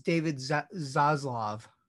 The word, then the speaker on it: Zaslav: Yeah, it's David Zaslav.